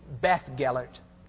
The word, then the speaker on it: Beddgelert: It's actually Beth Gellert, spelled B-E-D-D-G-E-L-E-R-T, because I know some of you go home to check these things. So you'll find it B-E-D, although it is pronounced B-E-T-H.